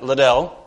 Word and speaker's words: Liddell: I mean, you might, I heard of Eric Liddell. Is that how we say his name? Not little, but Liddell, that fast guy.